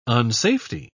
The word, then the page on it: unsafety: British English